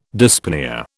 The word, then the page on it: dyspnoea: British English